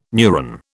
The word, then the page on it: neuron: British English